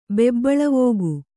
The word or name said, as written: ಬೆಬ್ಬಳವೋಗು